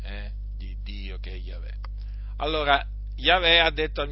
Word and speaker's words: Yahweh: Il tetragramma che appunto si pronuncia Yahweh e infatti lì il termine, eh, il nome, il nome di Dio in ebraico è Yahweh.